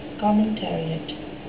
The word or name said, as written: commentariat